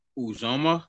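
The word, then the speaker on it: Uzomah: Maybe CJ Uzmaza. And I probably butchered his name. – You mean Uzoma? – Uzoma. Thank you.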